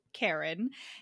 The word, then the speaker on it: Caron: So our heroine is Karon? Karen. So it's Karen, but it's spelled Caron, which was very odd.